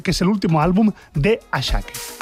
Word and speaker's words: Asake: Por cierto, el tema de los nombres Ashake, que se escribe Asake,